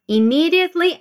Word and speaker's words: immediately: And her sentence continues with immediately after the results were announced. So she needed to do it immediately, but she didn't. Let's say it. Immediately Make sure you stress the second syllable immediately.